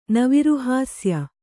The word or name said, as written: ನವಿರುಹಾಸ್ಯ